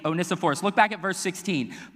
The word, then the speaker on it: Onesiphorus: I'm talking about a man named Onesiphorus.